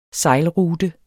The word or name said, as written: SEJLRUTE